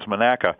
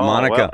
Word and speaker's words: Monaca: how to pronounce Monaca. – Oh, that's beautiful. Not Monica.